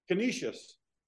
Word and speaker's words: Canisius: Canisius.